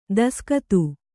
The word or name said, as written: ದೇಸಿಗಾತಿ